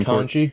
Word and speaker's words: Ketanji: No, the Supreme Ketanji.